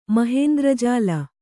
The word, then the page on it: ಮಹೇಂದ್ರಜಾಲ: mahēndra jāla